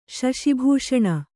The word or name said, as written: ಶಶಿಭೂಷಣ